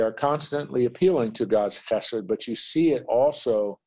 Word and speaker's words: chesed: In English, you would spell H-E-S-E-D. Sometimes it's spelled C-H-E-S-E-D, but it's guttural, chesed.